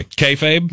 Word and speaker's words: kayfabe: Kayfabe? – That one.